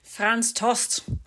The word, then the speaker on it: Franz Tost: Franz Tost.